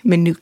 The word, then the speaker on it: Minooka: Minooka.